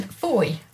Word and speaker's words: Fowey: And then Foy, Fowey. So that it gets called Fowey.